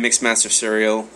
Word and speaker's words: Mixmaster Serial: I am Mixmaster Serial, spelled like it sounds, MixmasterSerial, all one word.